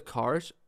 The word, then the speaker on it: Karsh: Am I saying that correctly, by the way? – Yeah, Josef Karsh, yeah.